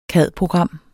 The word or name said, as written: cad-program